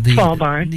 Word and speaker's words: Svalbard: and I'll probably butcher the way I say that, Svalbard. – Svalbard. – Yeah. – Uh-huh. – Oh, good. – No, that's good. – I was close. – You got it.